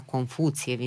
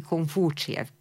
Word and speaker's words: Konfucijev: Pa, jeste, dosta ljudi je uglavnom izgovara Konfučijev institut. Ono zbog čega je pravilnije, mada pravopis sam srpskog jezika, prednost jeste daje Konfuciju, ali nije nepravilno Konfučijev, znači oba oblika su upotrebi.